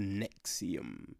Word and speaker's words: NXIVM: So it's spelled NXIVM, but it's pronounced Nexium.